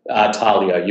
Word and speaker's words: Talio: So if anyone's in Brisbane, they can go to, did I pronounce that correctly, Talio? – Talio, you're pretty close.